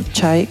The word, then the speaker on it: Chai: ya después de haber escuchado a Heinz y de haber escuchado a um, Chai, que para los que pregunten, así como Chai, como el té Chai, así se escribe Chai.